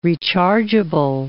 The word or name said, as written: rechargeable